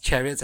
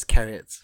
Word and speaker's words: chariots: And just point out, for a long, long time, I pronounced chariots as carrots for some reason. – Carrots of fire. – I thought the H was silent.